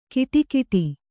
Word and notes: ಕಿಟಿಕೀಟಿ: kiṭikiṭi

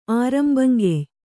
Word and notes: ಆರಂಭಂಗೆಯ್: ārambhaŋgey

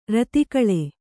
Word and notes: ಋತುಕಾಲ: řtukāla

